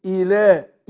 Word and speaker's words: إله: ملك الناس إله إي، يبقى انا قلنا الهمزه المكسوره المبدوء بها انطقها زي الاي بالانجليزي، مش زي الاي. اقول اي مش اي، اقول اله اي اله الهي اله الناس.